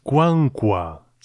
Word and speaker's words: Qwanqwa: Y el grupo al que hemos escuchado es Kwankwa. Se escribe Q-W-A-N-Q-W-A,